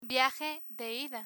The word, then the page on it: viaje de ida: Spanish